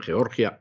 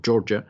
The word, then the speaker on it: Georgia: La cosa se ha puesto todavía mucho más caliente en Georgia o Georgia, si prefieren ustedes pronunciarlo así, donde un juez federal ha bloqueado de manera urgente las máquinas de dominio.